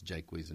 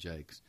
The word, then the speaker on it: Jaques: There's another pronunciation, of course, that's interesting, and that's Jaques and Jakes. Mm-hmm. And we use Jakes, do we not? – Mm-hmm. – We use Jakes, which is means toilet.